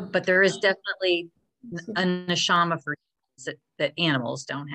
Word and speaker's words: Neshama: Neshama is what, and you said it right, Jacob.